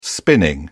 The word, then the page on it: spinning: British English